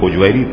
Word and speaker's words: ہجویری: حجویری